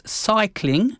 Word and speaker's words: scicling: It's cycling, and he spells it S-C-I-C-L-I-N-G. – Cambridge 105 Radio. – If you spell it, it sounds exactly the same as cycling. – Cycling. – But it's not spelled the same. It combines science, S-C-I, with cycling, the last bit of cycling, C-L-I-N-G.